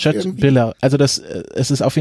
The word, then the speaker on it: Köttbullar: Es geht wieder um ein Fleischgericht, nämlich kleine Fleischklöße aus Rindfleisch, die, wenn man ihn jetzt unwissend aussprechen würde, den Namen Köttbullar heißen. Aber ich habe mich davor ausreichend informiert und ich werde jetzt mal versuchen, das richtig auszusprechen, nämlich Köttbullar.